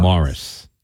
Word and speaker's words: Maurice: So Maurice River came from the one over there where they pronounce M-A-U-R-I-C-E as Morris. – Morris.